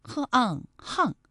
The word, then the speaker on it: 巷: 另外还有一个读音啊，是巷，呵昂巷，就是巷道的意思。那其实在读巷道的时候，可能有些朋友就会误读为巷道，那其实是不对的哈。